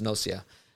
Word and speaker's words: Gnosia: G-N-O. – G-N-O-S-I-A. Yeah. I think it's pronounced Nocia.